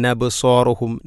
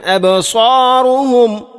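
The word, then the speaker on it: അബ്സാറുഹും (أبصارهم): നൂനെക്കൊണ്ട് ഇങ്ങനെ ഖാശിഅതൻ നബ്സാറുഹും നബ്സാറുഹും, ഈ ഒരു വായന കേൾക്കാവുന്നതാണ്. അത് ശരിയല്ല. ഖാശിഅതൻ അബ്സാറുഹും ഖാശിഅതൻ അബ്സാറുഹും തർഹഖുഹും ദില്ല.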